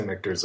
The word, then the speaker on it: Michter's: I say Michter's. – Yeah, I say Michter's as well. – Okay. We can ask them because sometimes they will answer us. – Sometimes. – So I did half Templeton, half Michter's.